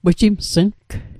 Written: wejimsng

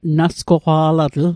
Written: nasgoqwa'latl

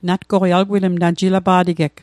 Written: jilapa'tegeg

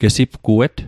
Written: gesipguet

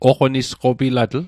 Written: oqonisqopilatl

